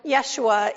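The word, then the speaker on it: Yeshua (ישוע): So the name Jesus, or Joshua, properly pronounced Yeshua in Hebrew, means God saves.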